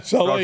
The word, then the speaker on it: Saleh: He says, our head coach's name is pronounced Saleh. Is that what it is? Saleh? – Yeah, Saleh.